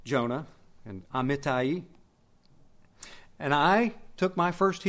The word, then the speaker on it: Amittai: I know how to pronounce that word in Hebrew, Amittai. I don't really know how to pronounce it in English, so I just say Amittai.